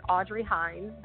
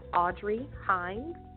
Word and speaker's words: Audrey Hinds: It's Audrey Hinds. The last name is spelled H-I-N-D, like David S. And on Facebook, my follow is at Audrey Hinds, the author.